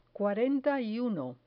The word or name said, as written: cuarenta y uno